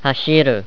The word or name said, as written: hashiru